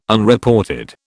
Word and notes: Unreported: British English

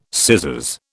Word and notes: scissors: British English

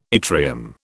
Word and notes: yttrium: British English

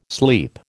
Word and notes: sleep: American English

